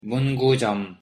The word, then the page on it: 문구점: Korean